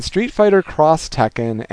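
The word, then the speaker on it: Street Fighter X Tekken: where we have Namco crossing with Capcom in Street Fighter Cross Tekken and Tekken Cross Street Fighter. Now, the cross is actually an X, but it's pronounced cross.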